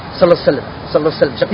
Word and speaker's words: Sallallahu Alaihi Wasallam: Ich habe nach dem Lam dieses Elif, was danach kommt, also diese, was also wie A anhört, das habe ich nicht richtig ausgesprochen. Sallallahu Alaihi Wasallam.